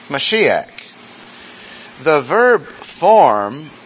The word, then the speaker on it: Mashiach: It's in Hebrew pronounced Mashiach. And if you're a purist, the CH on the end is like that. Mashiach. Mashiach.